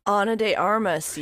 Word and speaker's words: Ana de Armas: And on the set of that, he met Ana de Armas. Is that how you say it? – Ana de Armas, yeah.